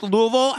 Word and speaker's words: Louisville: Can I tell you also, having done my show in Louisville, it is pronounced Louisville. And I really have learned, like, I was saying Louisville like everybody else for as long as I can remember. They schooled me. I will never say it wrong again.